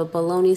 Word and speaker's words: bologna: This would be a good day to have a bologna sandwich for lunch. Sometimes the specialty meat is spelled bologna, B A L O N E Y, since that's how many people pronounce it.